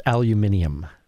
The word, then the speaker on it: aluminium: I thought the birthplace of aluminum was in the sun. – That's aluminium. – Oh. – For our that goes back to your British show.